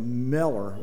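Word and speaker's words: Müller: Mueller, actually, his name was M-U circumflex L-L-E-R, so it was Americanized to M-U-E-L-L-E-R, which we call Mueller, but he always pronounced it more like Meller or something more like that.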